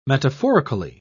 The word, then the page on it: metaphorically: British English